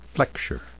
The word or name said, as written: flexure